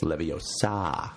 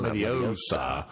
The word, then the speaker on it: Leviosa: She's like, "You're pronouncing it wrong. It's Leviosa. – Not Leviosa."